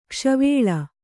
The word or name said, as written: ಕೋಸುವಲೆ